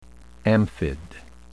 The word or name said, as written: amphid